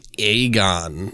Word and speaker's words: Egon: I think it's like Aegon, but it's spelled like Egon, yeah, like from the Ghostbusters.